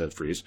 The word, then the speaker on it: Fries: I think Ed Fries, yeah, that's actually how you pronounce it. It's spelled Fries, but it's Ed Fries.